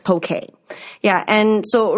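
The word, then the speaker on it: poké: So I think poke is, and it is spelled P-O-K-E. It has nothing to do with Pokemon. – Right. – And it is pronounced poke. – Mm-hmm. – We put the accent on the E because with Canadians, I think we're familiar with the accents and pronouncing it poke. And it just makes it easier.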